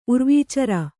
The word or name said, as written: ಉರ್ವೀಚರ